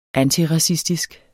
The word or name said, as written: antiracistisk